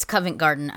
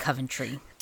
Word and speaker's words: Covent Garden: It's Covent Garden. I said it wrong earlier. I said Coventry.